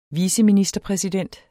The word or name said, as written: viceministerpræsident